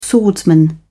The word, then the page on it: swordsman: British English